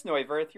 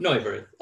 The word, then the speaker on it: Neuvirth: He was fine, you know, but and then Neuwirth, I see you over there. Give me that pronunciation. How'd I butcher that? – It's Neuvirth. You were close. – Neuvirth.